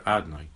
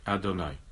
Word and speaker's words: Adonai: It is pronounced in davening, however, Adonai, Adonai.